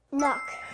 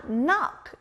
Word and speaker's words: knock: Knock. – That's right! 두드리다, knock. 여기도 끝에 k 소리가 나요.